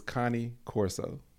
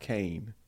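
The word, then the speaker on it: Cane Corso: And the name is Connie Corso. Mm. It's CaneCorse. Connie Corso, not Kane. It's Italian. It's pronounced Connie.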